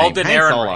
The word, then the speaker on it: Alden Ehrenreich: Alden Ehrenreich. – I'm not a fan of him. Alden Ehrenreich, that guy.